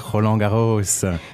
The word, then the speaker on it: Roland Garros: About Roland Garros.